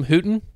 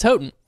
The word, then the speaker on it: Houghton: I thought yesterday I pronounced them Houghton. Multiple times. It's Houghton.